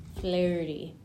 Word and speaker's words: Fleurty: But it's spelled F-L-E-U-R-T-Y. – Thank you. – Flirty. – Yes.